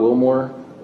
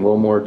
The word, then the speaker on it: Willmore: Jared Scott Wilmore, J-A-R-E-D-S-C-O-T-T, and Wilmore, W-I-L-L-M-O-R-E.